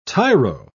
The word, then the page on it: tiro: British English